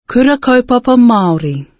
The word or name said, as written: kura kaupapa Maori